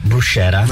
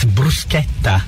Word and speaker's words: bruschetta: It's not bruschetta. – No. – It's bruschetta.